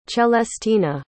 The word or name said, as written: celestina